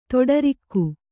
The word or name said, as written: ತೊಡರಿಕ್ಕು